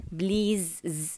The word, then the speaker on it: please: please لاحظوا هي الكلمة فيها S ولكن تنطق بالزاي please زاي وليس please، هذا خطأ يقع فيه الكثيرين فبعدوا عنه please بالزاي مو بالسين.